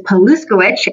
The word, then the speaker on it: Paluskiewicz: So for all of you listeners that are taking notes, my last name is Paluskiewicz and it is spelled the exact same way it sounds.